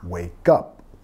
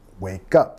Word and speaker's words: wake up: Nota la pronunciación, wake up, wake up. Tenemos que vincular ahí.